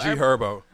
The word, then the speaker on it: G Herbo: Herb or G Herbo.